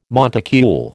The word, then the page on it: monticule: American English